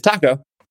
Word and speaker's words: Taco: Taco, whose real name is Taco. – And it's T-A-C-O? – T-A-C-O.